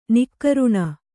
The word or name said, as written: ನಿಃಕರುಣಿ